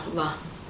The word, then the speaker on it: Veda: The va, you pronounce va or wa? – Which wa? Where? – This, I mean, Veda. – Yeah, Veda. Is that wa? Or? – Yeah, va. – Va.